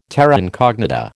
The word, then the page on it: terra incognita: American English